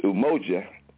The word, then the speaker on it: umoja: Principle number one is umoja, and that's spelled U-M-O-J-A, umoja.